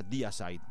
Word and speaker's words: Deicide: Se pronuncia Deicide, melón.